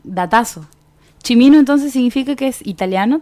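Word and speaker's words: Cimino: Aquí me mandaban un mensaje, me estaban, estaban escuchando y me dicen, se pronuncia Michael Chimino.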